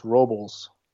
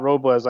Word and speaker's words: Robles: I found out his her name's actually it's pronounced Robles. – Oh, is it? – Yeah, it's not Robles. I I thought it was.